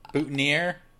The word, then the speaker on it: boutonniere: I don't know if this was scripted or this is Lithgow improvising, but he rips the flower, the butinae. How do you pronounce that? The butinae? The butinaire? I think I got it. Butinaire off his coat. The little flower. Butinaire? – Boutonniere, yes.